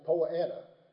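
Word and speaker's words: Poa annua: In the South, it's Poanna. It's three syllables. In the Deep South, it's Poanna. It's two syllables. Annual bluegrass, winter bluegrass, Poanua, and in Europe, annual meadowgrass.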